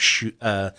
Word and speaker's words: Suger: Suger. – Yes. – That's the S. – Yes. Yep. – It's spelled like sugar, but with an E instead of an A.